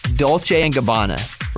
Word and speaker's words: Dolce & Gabbana: Dolce & Gabbana, right? That's how you say it? – Yeah.